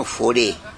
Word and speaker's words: Faure: Mi padre, Milciades Ribano Foré. – No, Foré, ¿y cómo se escribe Foré eso? – ¿Igual? Faure. – Faure, sí. Como francés.